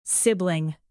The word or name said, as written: sibling